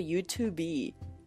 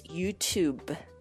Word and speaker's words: YouTube: 但是呢，同样的发音规律，你不能用在 YouTube 上面，你不能说 YouTube。 – To be, right, yeah. – It's YouTube.